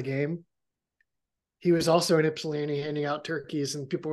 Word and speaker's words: Ypsilanti: Ypsilanti. – What? Say it. – Ypsilanti.